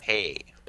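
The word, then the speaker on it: Dampé: Dompé.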